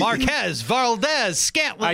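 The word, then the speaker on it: Valdes-Scantling: Marquez Valdez-Scantolini? – Scantolini. – Marquez Valdez-Scantling.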